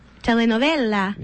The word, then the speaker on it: telenovela: It's technically a telenova. – telenovela. – Yeah, that's, yeah. Thank you for saying it right.